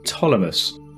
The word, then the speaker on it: Ptolemaeus: I'm going to call it Ptolemaeus. It's P-T-O-L-E-M-A-E-U-S. And I believe it's usually a silent P, so I'm going with that.